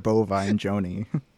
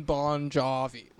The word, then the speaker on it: Bon Jovi: Mr. Bovine Joni. – Yeah, so, like, I was going to say, like, they can't really say his name correctly. Like, John Bon Jovi.